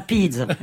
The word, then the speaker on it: Pise: C'est la bonne prononciation, c'est la Pise.